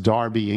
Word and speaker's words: Derby: Brian Pyle in Derby, probably pronounced Darby, England.